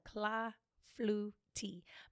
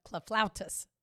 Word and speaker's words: clafoutis: I was calling it clafoutis. Because it has an S on the end, you know, yeah. Has a French S.